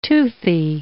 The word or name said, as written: toothy